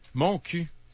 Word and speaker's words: Montcuq: Mon cul. Mais ça s'écrit M-O-N-T-C-U-Q.